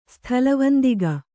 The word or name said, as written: ಸ್ಥಲವಂದಿಗ